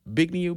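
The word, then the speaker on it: Zbigniew: but do you know who Bigniew Brzezinski is in his There's a Z at the beginning of his name, but the Z is silent in English pronunciation.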